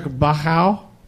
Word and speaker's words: Bauchau: It's spelled B-A-U-C-H-A-U. Bachow or Bachow.